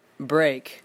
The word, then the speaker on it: Brake: You can get me at Blake dot Break. You know, kind of like how like an Asian person would say my name, like hero Break.